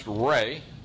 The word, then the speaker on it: Ra: And sometimes you're going to see his name spelled R-E, pronounced Ray. If you have trouble remembering that, think of a sun, Ray. Sometimes you're going to see it spelled with an A, pronounced Ra.